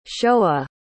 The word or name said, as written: shoah